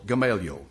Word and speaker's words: Gamaliel: The accent in my Bible is on the second syllable. Gamaliel.